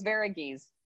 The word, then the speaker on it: Varughese: Varughese.